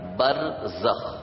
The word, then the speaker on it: برزخ: دنیا اور آخرت کے درمیان ایک عالم ہے جس کو برزخ کہتے ہیں, جس کو برزخ کہتے ہیں, با جس کو بے بولتے ہیں اردو میں, را جس کو رے بولتے ہیں, پھر زے, پھر اس کے بعد خے, یعنی جو اردو تلفظ ہے اس کے مطابق برزخ.